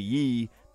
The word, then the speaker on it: Ye: Yee, to be pronounced Ye,